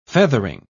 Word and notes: feathering: British English

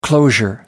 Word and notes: closure: American English

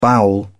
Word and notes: bowel: British English

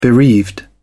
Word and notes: bereaved: British English

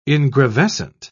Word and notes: ingravescent: British English

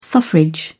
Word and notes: suffrage: British English

